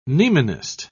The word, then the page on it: mnemonist: British English